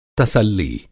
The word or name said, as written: तसल्ली